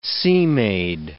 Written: sea-maid

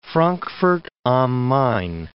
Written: Frankfurt am Main

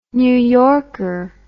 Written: New Yorker

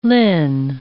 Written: linn

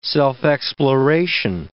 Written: self-exploration

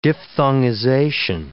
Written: diphthongization

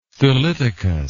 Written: thelytokous

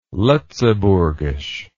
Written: letzeburgesch

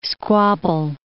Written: squabble

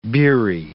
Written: beery

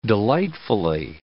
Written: delightfully

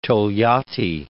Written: Togliatti

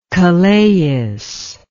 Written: kalais

